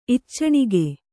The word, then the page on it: ಇಚ್ಚಣಿಗೆ: iccaṇige; Kannada